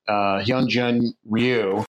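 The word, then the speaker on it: Hyun-jin Ryu: I'm mispronouncing his name. Is it Hyunjin Ryu? Mm-hmm.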